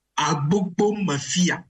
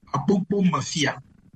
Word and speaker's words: Agbogbo Mafia: So, Frederick sent me the right pronunciation of the title that I was struggling to pronounce. So I think it is Agbogbo Mafia. – Mm. – Yeah, the title is double.